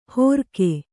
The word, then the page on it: ಹೋರ್ಕೆ: hōrke